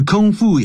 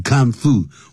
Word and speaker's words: kung fu: 这 kung fu 也可以念成 kung fu、啊、两个念法都可以 kung、啊、等于 kung fu 或者是 kung fu 这样子。好，D by known be known by something，